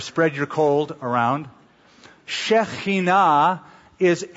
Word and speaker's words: Shekinah: They called this the Shekinah, that's how most of us pronounce it. Shekinah is the correct pronunciation.